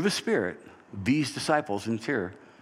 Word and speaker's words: Tyre: and they land at this town that I've always called Tyre, T-Y-R-E, but its actual pronunciation is Tyros, but I'm not going to call it Tyros because it doesn't make sense.